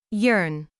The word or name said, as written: yearn